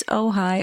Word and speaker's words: Ojai: Like Ojai, the Strymon Ojai. – Right. – And everyone was so nice about that in the comments. They're like, it's Ojai.